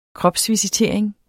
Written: kropsvisitering

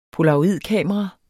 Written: POLAROIDKAMERA

